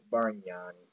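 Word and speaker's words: Bargnani: Bagnarni. – Bagnarni? – The G moves silent like lasagna. That's all good. – Bagnarni.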